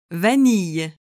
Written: vanille

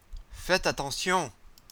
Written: faites attention

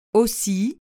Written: aussi

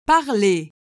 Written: parler

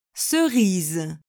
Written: cerise

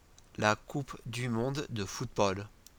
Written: la coupe du monde de football

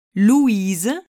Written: louise

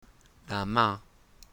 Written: la main

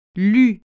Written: lu

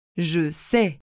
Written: je sais

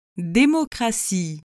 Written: democratie

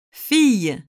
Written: fille

